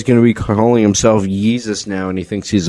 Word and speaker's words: Yeezus: Can you say Yeezus? – Yeezus. – Y-E-E-Z-U-S.